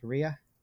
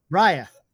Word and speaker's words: Raya: I said it right, right? Rhea? Nope. Raya.